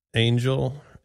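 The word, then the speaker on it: Angyal: Her name is Chloe Angel. It's A-N-G-Y-A-L. She says it's pronounced Angel, which I don't know if that's true.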